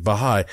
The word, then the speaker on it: Baha'i: and I looked it up, I am pronouncing it correctly, I believe, Baha'i.